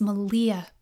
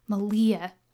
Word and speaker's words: Malia: I whispered to her ear, my name is Malia, pronounced with a long E sound, like Malia. It rhymes with Maria.